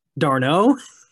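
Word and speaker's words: d'Arnaud: Sometimes you just gotta sign a guy and hold on. Pronounce that again. – DiNardo. – Darno. – Oh, it is Darno. I say it wrong.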